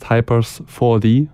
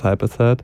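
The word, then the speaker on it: Hypers4t: A lot of people say just Hypers 4D, like it's like a 3D movie or something. But I would like it to be pronounced just as Hyperset.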